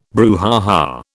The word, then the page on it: brouhaha: British English